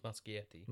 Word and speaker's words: Muschietti: Muschietti.